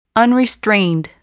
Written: unrestrained

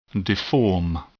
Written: deform

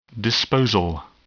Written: disposal

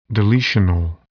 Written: deletional

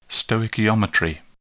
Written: stoichiometry

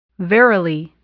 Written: virally

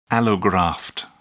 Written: allograft

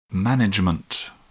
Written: management

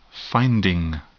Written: finding

